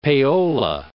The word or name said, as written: payola